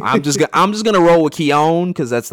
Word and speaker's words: Keoghan: on, it's like the Irish pronunciation is Keoghan, but I think in English, like some people say Keegan, some people say Kogan.